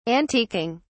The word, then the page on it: antiquing: British English